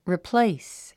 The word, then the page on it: replace: ripléis; リプレイス